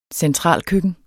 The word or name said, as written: centralkøkken